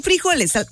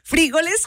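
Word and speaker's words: fríjoles: No, frijoles. – Frijoles. – Acento en la I. Frijoles.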